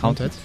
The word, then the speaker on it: Haunted: ¿Cómo se dice? Haunted. – Pues lo he dicho bien. Haunted.